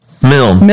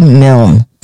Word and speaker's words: Milne: Andy, how you pronounce your last name? – Milne. – Milne. – Milne. Andy Milne. – Milne. It's like one syllable.